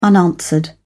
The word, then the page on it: unanswered: British English